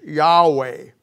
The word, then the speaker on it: Yahweh: And God's original name translated into the English as YHWH, and the way we pronounce it is Yahweh.